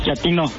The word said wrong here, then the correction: chatino: ¿Chapino? – No, chatino. – Es como chato, ¿verdad? Chatino. – Sí, azunero, como chato, chatino.